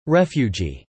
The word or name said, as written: refugee